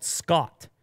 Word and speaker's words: Schott: It's another company, a German company called Scott, but not spelled the way you expect it to be spelled, Will. Mm. Spelled S-C-H-O-T-T, Scott. Mm. Scott. That's how it's spelled.